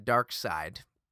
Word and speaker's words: Darkseid: And they, of course, said it's pronounced Darkseid. Like the dark side of the force, for example.